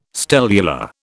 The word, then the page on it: stellular: British English